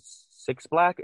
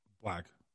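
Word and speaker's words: 6lack: And you got other features from 6 Black. Is that how you pronounce his name? – Black. – Just Black? Okay, so just Black.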